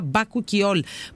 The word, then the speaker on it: μπακουκιόλ: Λοιπόν, τώρα που λέμε για αντιγύρανση και γενικότερα για το πώς διατηρούμαστε έτσι κάπως λίγο πιο φρέσκοι, τελευταία ακούω πολύ το μπακουκιόλ, κυρίες και κύριοι μου, και τώρα καταλαβαίνω γιατί. Είναι η δερμοκαλλυντική σειρά μπακουκιόλ που προφέρεται έτσι. Μπακουκιόλ, για να το ξέρετε,